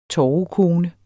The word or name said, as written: torvekone